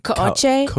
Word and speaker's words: Kouchi: That's how my dad pronounces it, but it's spelled K-O-U-C-H-I. So I think it's supposed to be like Koche.